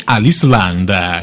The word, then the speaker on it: Aliceland: Abbiamo con noi Aliceland. – Ciao. – Benvenuta. – A tutti, ciao ciao, eccomi qua. – L'ho detta bene con accento inglese, sì. – Aliceland, perfetto.